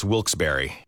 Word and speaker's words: Wilkes-Barre: And you're listening to Wilkes Bar Scranton's 97.9X. Now, I had heard that it was supposed to be pronounced Wilkes-Barre.